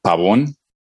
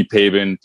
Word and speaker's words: Pabon: So, I mean, if you want it with the Spanish accent, it would be Pabon. But, I mean, people call me Pabon, people call me Pabon.